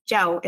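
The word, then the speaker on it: Châu: So she added Chow, and Chow is pronounced Jiao in Vietnamese, which could mean one or two things.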